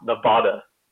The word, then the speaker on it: Nevada: First of all, Matt, it's pronounced Nevada.